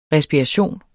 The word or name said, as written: respiration